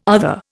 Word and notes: other: Indian

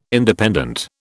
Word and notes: Independent: British English

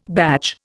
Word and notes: badge: Indian

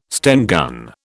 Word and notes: Sten gun: British English

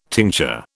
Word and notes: tincture: British English

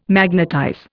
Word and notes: magnetize: Indian

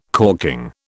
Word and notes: corking: British English

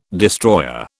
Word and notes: destroyer: British English

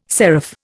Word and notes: serif: Indian

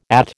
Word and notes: At: American English

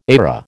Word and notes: ARA: American English

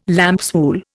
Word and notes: lambswool: Indian

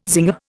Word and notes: zinger: Indian

